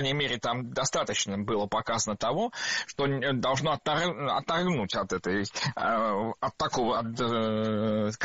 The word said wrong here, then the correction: отторгнуть: Отторгнуть. Неверно. Правильно, отторгнуть. Ударение в этом слове ставим на второй слог.